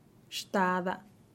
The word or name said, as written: xtada